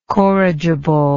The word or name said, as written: corrigibly